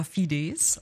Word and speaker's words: Fides: Also die Lateiner sagen sogar Fides auf der zweiten Silbe.